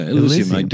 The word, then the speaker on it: Elysium: Elysium.